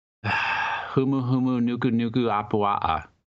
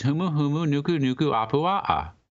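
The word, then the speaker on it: Humu humu nuku nuku apua'a (humuhumunukunukuāpuaʻa): It's so straightforward. – Well, now that you say, I still don't. I don't think I could still pronounce that. – It's all repeated words. Humu humu nuku nuku apua'a.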